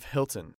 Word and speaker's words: Hilton: I have a reservation under the name of Hilton. – Could you spell that, please? – Sure. It's H-I-L-T-O-N.